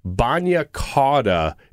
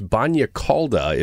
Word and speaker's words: bagna cauda: Now, I got to say, Bagna Cauda is the way that all of Collinsville pronounces it. But my wife, who you know, is Italian. Okay. And she says, no, it's Bagna Cauda. It means hot bath.